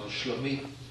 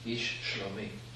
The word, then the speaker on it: ish shlomi: How do you pronounce that? Is it shalom? – Oh, shlomi. – Shlomi? – Yes. – This is the word here for friend. – Ish shlomi.